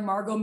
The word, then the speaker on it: Margaux: And, uh, you know, I'm on there, Margaux Miller.